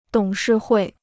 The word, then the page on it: 董事会: dǒng shì huì